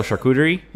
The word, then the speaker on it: charcuterie: I forget what it's called, like a chishery board, something like that. – Oh, charcuterie? – Yeah, that. I knew it started with a CH. I just never knew how to pronounce it.